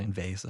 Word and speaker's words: Envase: How's that name pronounced for the? – Envase. – Yeah. I was wondering if it was going to be like a Danish pronunciation of Envase or something.